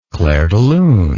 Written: clairdelune

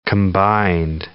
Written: combined

